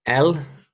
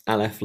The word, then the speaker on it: El: So as we say, we're looking at El, it's E-L in the English when it's transliterated. You'll sometimes also see it written as A-I-L, as how we might pronounce it. In the Hebrew, it's just two letters reading from right to left, Aleph Lamed. So it's this idea of El or Ale.